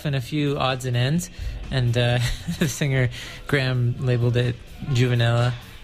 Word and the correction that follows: Juvenalia: How do you say that? – Juvenalia. – Juvenalia. What did I say? – Juvenella.